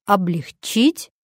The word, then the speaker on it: облегчить: Сейчас можно уже точно позволить себе заглянуть в словарь и узнать, что у этих слов совершенно другое ударение. Правильное ударение – облегчить, углубить.